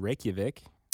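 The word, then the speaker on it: Reykjavík: So I called my thousands of friends in Reykjavik. That's how you pronounce that, right? – Yes.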